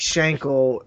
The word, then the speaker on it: Schenkel: Like, it rhymes with ankle. Like, okay, so you're saying Mike Schenkel was the one who was like just poking and prodding, and he just never shut up about it.